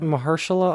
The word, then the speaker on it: Mahershala: I was going to say Muhammad Ali, but I was like, that's not how you pronounce his name. Mahasha or something. You know what I'm talking about? – Mahershala Ali? – Yeah. Yeah. Yeah.